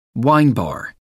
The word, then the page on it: wine bar: American English